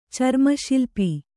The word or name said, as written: ಚರ್ಮಶಿಲ್ಪ